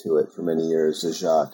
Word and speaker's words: Zagat: Yeah, which is how Zagat pronounces his name, right? – Correct, yeah. We often tell people it's Zagat like the cat. It's rhymes with cat.